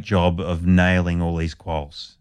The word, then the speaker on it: quolls: So let's talk about your research with the quolls. – Okay, so... – And I'm saying it right again. – Yeah, right? – Quolls, yes. Quolls.